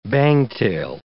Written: bangtail

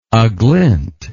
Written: aglint

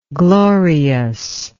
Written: gloriousness

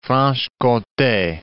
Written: Franche-Comte

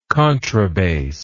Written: contrabassist

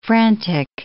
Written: frantic

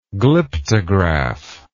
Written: glyptograph

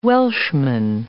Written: Welshman

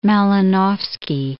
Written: Malinowski

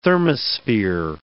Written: thermosphere

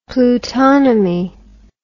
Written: plutonomy

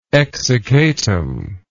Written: exsiccatum

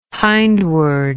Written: hindward

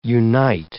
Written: unite

